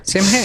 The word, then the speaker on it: Samhain: S-A-M-H-A-I-N. – Mm-hmm. – Sam Hay.